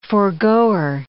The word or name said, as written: forgoer